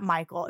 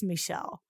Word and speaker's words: Michel: I'm like, it's not Michael. It's Michel.